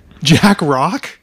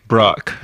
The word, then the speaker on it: Jack Brock: and I was like, how do you pronounce it? Jack Brock. Jack Rock? Brock.